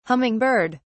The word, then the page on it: humming bird: British English